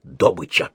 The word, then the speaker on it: добыча: Все газовики в обязательном порядке произносят слово «добыча» с ударением на первом слоге «добыча».